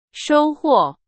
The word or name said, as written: 收获